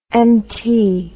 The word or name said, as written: Mt